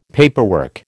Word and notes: paperwork: American English

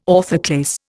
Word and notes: orthoclase: Indian

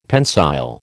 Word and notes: pensile: American English

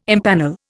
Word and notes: impanel: Indian

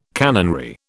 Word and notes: canonry: British English

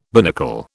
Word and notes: binnacle: British English

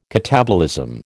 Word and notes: catabolism: American English

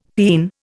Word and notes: peen: Indian

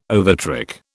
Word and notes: overtrick: British English

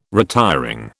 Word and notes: retiring: British English

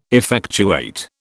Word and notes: effectuate: British English

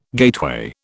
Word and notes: gateway: British English

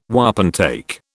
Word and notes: wapentake: British English

